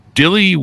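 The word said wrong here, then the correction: Dilys: Do I have that pronounced right? Dilly Wins? Dilly Wins? – Dillis. – Dillis Wins. Boy, they misspelled that. It's D-I-L-Y-S.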